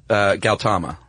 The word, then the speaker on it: Gautama: Gautama.